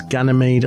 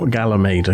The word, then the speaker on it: Ganymede: And just to point out to people, that's Ganymede and not Galamede, okay? – Ganymede. I can spell it for you. It's G-A-N-E-Y-M-E-D-E.